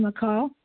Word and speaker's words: Michal: Good morning, McCall. – Hi, good morning. My name is McCall, and that's spelled M-I-C-H-A-L.